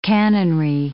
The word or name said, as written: cannonry